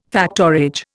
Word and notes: factorage: Indian

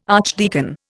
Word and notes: archdeacon: Indian

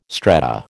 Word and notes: strata: American English

